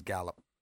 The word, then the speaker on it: Gallup: At least we got to recognize Gallup.